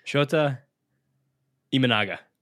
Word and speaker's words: Shota Imanaga: Shota Imanaga.